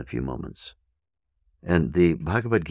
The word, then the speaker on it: Bhagavad: Bhagavad is spelled B-H-A-G-A-V-A-D. Bhagavad. B-H-A-G-A-V-A-D. Bhagavad. Second word, Gita. G-I-T-A.